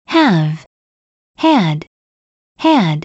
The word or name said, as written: have had had